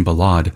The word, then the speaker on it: ballade: This next piece probably takes the French pronunciation ballade.